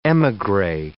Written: emigre

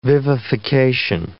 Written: vivification

